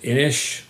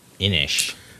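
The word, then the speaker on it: Inis: Apparently they pronounce it Innish, which is the Celtic way of saying island. – Innish. – You wouldn't think so because it doesn't have an H on the end, but that's what I've come to hear.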